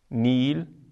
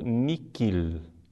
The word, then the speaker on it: nihil: A segunda palavra, nil, deve ser pronunciada como se o H não existisse. É assim que se pronuncia em Roma. Uma nota de rodapé. Existe uma antiga pronúncia eh, portuguesa que para a Igreja foi abolida por São Pio X, onde o nil é pronunciado niquil, com que. Mas essa deve ser deixada de lado.